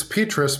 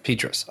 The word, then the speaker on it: Petrus: It's Petrus, but that's okay. Petrus.